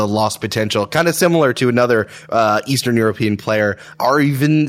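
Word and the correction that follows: Arvydas: Sabonis. – Arvidas Sabonis. – Thank you. I had so much trouble pronouncing that.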